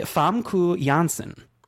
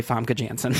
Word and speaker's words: Famke Janssen: This is a funny one, because she's gone by many pronunciations over the years. It's actually Famke Janssen. But a lot of people just say Famke Janssen.